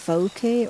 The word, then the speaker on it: wauke: And one of them is the W-A-U-K-E, which I can't pronounce properly, but they call it the cloth plant. Talk about the cloth plant. – The vouke, or the paper mulberry,